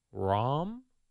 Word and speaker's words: Rahm: This golfer, John Rahm? Did I say that correctly? – You did, yes.